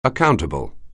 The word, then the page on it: accountable: anglais